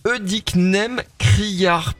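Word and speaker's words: Œdicnème criard: Euh, Eudicnem Criard prévue ce mercredi déjà, est-ce que j'ai bien prononcé oui, tout à fait, vous avez bien prononcé.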